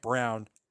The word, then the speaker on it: Braun: It's spelled B-R-A-U-N, but it's pronounced Brown.